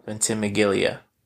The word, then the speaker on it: Ventimiglia: Hold on, I'm going to say it right. Ventimiglia.